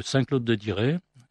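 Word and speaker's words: Saint-Claude-de-Diray: Le siège est à la mairie de Saint-Claude-de-Diré. Alors, il faut écrire à mairie 41-350 Saint-Claude-de-Diré. Ça s'écrit, Diré s'écrit D-I-R-A-Y.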